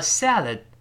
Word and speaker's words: salad: 沿着上牙根往后滑一下，反正 no I mean 就是我是说 a salad 就是一个沙拉，s a l a d 读慢了叫 salad，注意前面发 a，然后后面发 a，所以读成我们大声读一下 salad，不要读沙拉的，不是沙拉，这这不能这么读，叫 salad salad 这么一个声音，读快了之后，最后那个的。